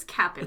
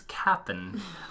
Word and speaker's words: captain: I also noticed that you're pronouncing captain. – I know. – It's cap'n. – It's cap'n, but I'm not good at those types of words.